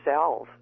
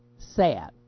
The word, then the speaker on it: salve: S-A-L-V, as in Victor, E. S-A-L-V-E. And not surprisingly, Susan and I both say it the same way, which is Salve. – Sav. – Her daughter. Her daughter says salve.